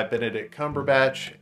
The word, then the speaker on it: Benedict Cumberbatch: It brings in a whole new world uh, to Marvel, introduces a new character in Stephen Strange, um, played by Benedict Cumberbatch, and.